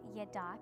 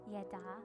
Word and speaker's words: Yadah: And one of the ones that we want to talk about is called Yada. Can you all say that with me? Yada. Yeah, it's spelled Y-A-D-A-H, and it means literally to lift your hands in praise.